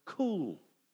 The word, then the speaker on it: kuwl: The word is in Hebrew, and the best spelling we have it in English is K. U-W-L. And how great is this? It's pronounced, even in Hebrew, it's pronounced cool. Cool.